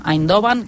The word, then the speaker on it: Eindhoven: Nosaltres, a la ràdio, pronunciem Eindhoven, que és com s'ha de pronunciar en el català.